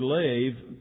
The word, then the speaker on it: lev: Since it's not on the screen, we're going to have to put L-E-V. L-E-B. It's pronounced L-E-V, lev. But L-E-B is the Hebrew word, and it's the broadest word for the inmost part of us that's found in the Bible.